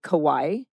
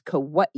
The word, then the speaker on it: Kauai: So Americans say Kauai, and that's kind of from, it's kind of a leftover. It started during World War II and after World War II. And it's improper to say Kauai, but it's Kauai is the correct pronunciation.